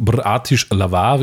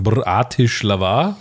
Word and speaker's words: Bratislava: Und wir waren auch absolut auf Zuckerschock unterwegs, meine Damen und Herren, in Bratislava, wie ich sage, weil so ist eigentlich die Aussprache. Das heißt nicht Bratislava, sondern Bratislava. Und das muss ich ja blind glauben, weil ich habe davon noch nie gehört.